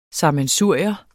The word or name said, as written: SAMMENSURIER